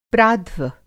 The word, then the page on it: प्राध्व: Hindi